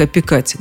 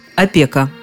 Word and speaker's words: опека: Правильно говорить опека. Орфоэпический словарь под редакцией Аванесова, который вообще редко пользуется знаками категорического запрещения, в данном случае совершенно неумолим. Рядом со словом «опека», а именно так многие привыкли его произносить, в словаре стоит строжайший восклицательный знак. И помета неправильно.